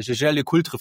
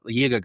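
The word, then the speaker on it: Jaeger-LeCoultre: rumtreiben und sich dann darüber in den Kommentaren darüber aufregt, dass der junge Brillenträger Jaeger LeCoultre falsch ausgesprochen hat, Jäger gesagt hat.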